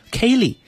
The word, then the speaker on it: ceilidh: 嗯，这个舞如果要是发音叫 Kelly，它拼写是 C-E-I-L-I-D-H，但是发音叫 Kelly。